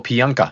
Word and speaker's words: Pijanka: PJ Pijunka? Is that right? – No, it's, you know, and it's an interesting little tidbit about me. The J is silent, my last name. – Oh, Pijunka.